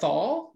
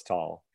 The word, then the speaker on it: Neanderthal: It's not Thal? – No, it's Tall.